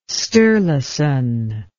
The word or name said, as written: sturluson